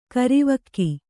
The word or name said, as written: ಕರಿವಕ್ಕಿ